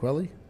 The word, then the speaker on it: Kweli: Uh, we got uh, Talib Kweli at Wally's at 9 p.m. Is that how you say it, Kweli? – Uh, Talib? Kweli. – Talib yeah. Kweli. Yeah. He's actually pretty awesome.